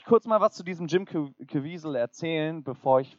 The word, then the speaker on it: Jim Caviezel: Das ist nicht Jesus, das ist nämlich der Herr Jim Caviezel. So spricht man den übrigens aus, Koray.